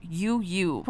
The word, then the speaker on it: UWUW: And something else interesting, we'll talk about the the name of your group as well because it's spelled U W U W all caps. And you're telling me it's pronounced U U.